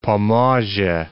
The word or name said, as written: Pomorze